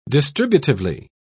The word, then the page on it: distributively: British English